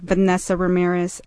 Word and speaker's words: Vanessa Ramirez: And I said, I am Vanessa Ramirez. And that was it. – Mm-hmm. – I had the emphasis on the va and the ram, Vanessa Ramirez. And I was Vanessa Ramirez for a really long time.